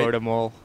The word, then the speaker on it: Mål: How do you say like goal? How, you know, what's that in Norwegian? – Mål. – Mål? – Mål. – Mål? Okay. – Okay. – Spell that. – M and then it's A with a dot over. – Okay. – And then L.